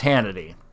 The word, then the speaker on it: Hannity: was when Hannity and Combs went to just Hannity. And they got rid of Combs. – Yeah. Without Combs, Hannity is really just, it rhymes with insanity for a reason.